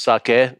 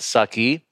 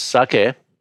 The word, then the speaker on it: sake: So the reason we're doing sake at all is because, and first of all, it's pronounced sake, not sake. For those out there, it's like the word socket, like a socket wrench, but without the T. So it's sake.